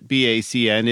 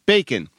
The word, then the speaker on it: BACN: He taught me the proper pronunciation of BACN, which is not BACN, it's bacon.